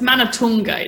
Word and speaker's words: Manathunga: You're very close. It's Manathunga. It's Sri Lankan.